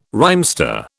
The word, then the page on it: rhymester: British English